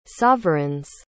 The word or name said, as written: sovereigns